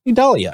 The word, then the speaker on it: Idalia: Idalia.